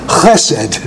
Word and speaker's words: chesed: They usually say chesed or something like that, but it's not. It's chesed. It's a strong vowel, chesed, with a guttural.